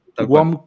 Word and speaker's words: WOM: corríjame eh, corrígeme si me equivoco, la pronunciación es WOM, la, empresa de telecomunicaciones chilena, ¿no? – WOM